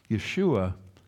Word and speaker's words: Yeshua: The word for salvation is Yeshua. Yeshua is the Hebrew pronunciation of the word Jesus.